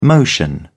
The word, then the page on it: motion: British English